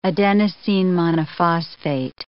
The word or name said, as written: adenosine monophosphate